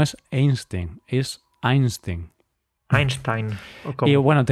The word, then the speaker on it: Einstein: ¿Sabes que no se dice Einstein, Paco? ¿Cómo se dice? Cuéntame. Einstein.